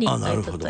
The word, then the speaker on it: 鈴と糸: はい、ひらがなで書いていて、はい、一応あの意味が分かりやすいように後から漢字にしたのが鈴と糸と書いてリンと糸と言います。